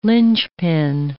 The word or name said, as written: linchpin